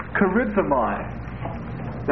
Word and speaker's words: charizomai: Now there's a second word that's used, charizomai. That's also used in the New Testament. Spelled like this. Charizomai, C-H-A-R-I-Z-O-M-A-I. And actually